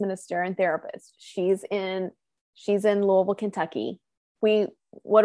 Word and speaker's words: Louisville: You're in Louisville.